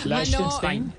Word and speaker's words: Liechtenstein: Liechtenstein.